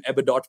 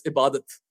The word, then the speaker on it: Ebadot: But no, it's Ibadat. Ibadat Hussain.